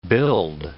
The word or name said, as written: build